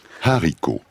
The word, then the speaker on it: haricot: Le haricot, phaseolus de la famille des Fabaceae, se prononce avec un H aspiré et se cuisine avec un R inspiré. Haricot, pas haricot. On ne fait pas la liaison.